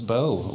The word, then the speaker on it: Beau: It's easy when your name's Beau. A lot rhymes with it.